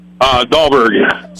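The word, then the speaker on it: Dalberg: Dahlberg. – Spell it. – D as in David, A-L, B as in boy, E-R-G.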